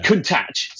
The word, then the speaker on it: Countach: But you've got to get the pronunciation right, or it means something slightly different or something. I can't quite remember what it was. Swearing, probably. But Countach seems to be the correct pronunciation.